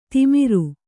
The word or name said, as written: ತಿಮಿರ